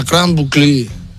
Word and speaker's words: Grande Boucle: ¿Sabéis que el Tour de Francia es la Grand Boucle? La Grand Boucle, que se escribe la Grande Boucle.